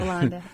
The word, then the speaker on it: Hollande: Everybody waited for the United States and President, I'm trying to pronounce his name, Hollande. You know, I don't think you pronounce the H over there. – Hollande, yeah.